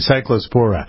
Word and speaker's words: cyclospora: This is cyclosporola, or I never pronounce that right, cyclospora.